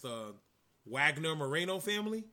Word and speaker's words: Wagner: who is the son of Dr. Wagner Jr. Did I say that right? – Yep.